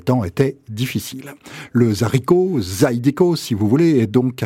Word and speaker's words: Zydeco: le Zydeco. Ça s'écrit Z-Y-D-E-C-O, on peut le prononcer un peu comme on veut, à l'anglaise ou à la française, ça n'a pas d'importance.